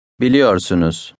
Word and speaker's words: Biliyorsunuz: Biliyorsunuz.